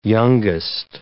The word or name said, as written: youngest